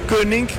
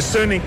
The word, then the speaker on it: König: Y The König, o The König, como ustedes lo pronuncian acá, en realidad significa el rey.